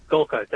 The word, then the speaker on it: Golka: Is it Golka? Is that how you say his name? – Golka. That's correct. Yep.